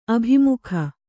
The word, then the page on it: ಅಭಿಮುಖಿ: Kannada